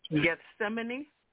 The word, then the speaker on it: Gethsemane: How do you pronounce that word? – Gethsemane. – Okay, right, that one.